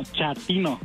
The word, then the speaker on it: chatino: Chapino. – ¿Chapino? – No, chatino. – Es como chato, ¿verdad? Chatino. – Sí, asunero, como chato, chatino.